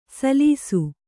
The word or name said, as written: ಸಲ್ಸು